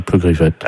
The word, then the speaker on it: Plougriffette: Comme ça se prononce, hein, Plougriffette.